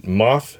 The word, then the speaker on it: Moff: His character's name is Moth looks like Gideon, Moff Gideon, but he pronounced it Moff Gideon.